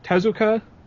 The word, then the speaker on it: Tezuka: But, anyway, this anime movie is based off of Osamu, if I can pronounce his last name right, Tezuka? – Tezuka.